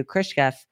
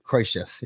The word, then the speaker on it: Khrushchev: In 1962, Khrushchev wrote to Kennedy to defuse the Cuban Missile Crisis. And I know he said his name wrong. – Khrushchev, yes.